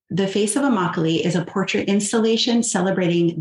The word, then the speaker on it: Immokalee: People of Immokalee. Did I pronounce that right? – Yes, Immokalee.